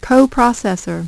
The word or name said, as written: coprocessor